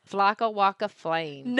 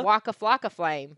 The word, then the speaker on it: Waka Flocka Flame: Is that, did I say it right? Flocka Waka Flame. – No. – Waka Flocka Flame. – Yes.